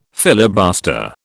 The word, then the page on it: filibuster: British English